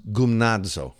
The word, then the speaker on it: gymnazo: Now, the word translated exercise or discipline or train in the Greek, it's pronounced gymnazo. Okay, then that's probably not great because my Greek pronunciation is not super. But it's spelled, check this out, G-Y-M-N-A-Z-O, like gymnasium.